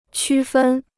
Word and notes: 区分: qū fēn

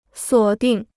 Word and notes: 锁定: suǒ dìng

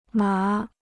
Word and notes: 麻: má

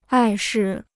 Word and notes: 碍事: ài shì

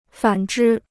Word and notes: 反之: fǎn zhī

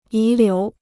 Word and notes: 遗留: yí liú

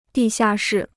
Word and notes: 地下室: dì xià shì

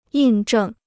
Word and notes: 印证: yìn zhèng

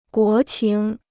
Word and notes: 国情: guó qíng

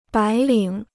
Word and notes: 白领: bái lǐng

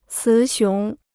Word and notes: 雌雄: cí xióng

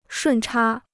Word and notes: 顺差: shùn chā

